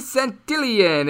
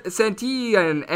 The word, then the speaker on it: Santillan: Meanwhile, Antonio Senzatela is going to be going for the Colorado Rockies with Santillan and the Reds. Hopefully, I'm saying that one correctly. Apparently, it's a silent L